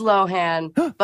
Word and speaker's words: Lohan: I've been saying Lindsay Lohan, but is it Lohan or Lohan? Can you please correct us? – I've said Lohan. I've said Lohan. It is Lohan, but it doesn't matter.